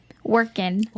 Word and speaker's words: Workin': Without the G. Workin'. – Yeah, that's what I said. – Moms. – Workin'. – Yeah, I kept forgetting that, you know, you don't pronounce the G.